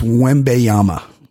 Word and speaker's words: Wembanyama: Very close. Pronounce his name again for me. – Wayamba. – Very close. – Wayamba? – I believe it's Wembanyama. Wembanyama.